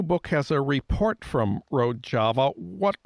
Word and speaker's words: Rojava: And the New York Times reported last fall about this autonomous Kurdish region called Rojava. Am I pronouncing that right? – Rojava, an autonomous Kurdish region in Syria, which, according to the New York Times, is led by militant feminist anarchists.